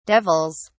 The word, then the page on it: devils: British English